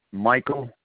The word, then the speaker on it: Michael: Michael. It's spelled M-I-C-H-A-E-L. Roy. R-O-Y.